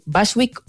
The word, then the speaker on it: Bushwick: Esta canción, que va a sonar, se titula, a ver si lo digo bien, Bushwick Outcast. – Claro, la U es como una U, en realidad.